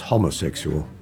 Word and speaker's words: homosexual: Homosexual. – Good gracious, no. By the way, it's homosexual. – Is it? – With a short O from the Greek, not Latin.